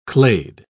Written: clade

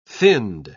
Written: finned

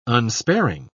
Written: unsparing

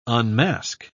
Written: unmask

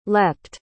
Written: leaped

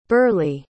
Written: burleigh

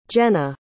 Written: jenna

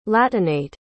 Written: latinate

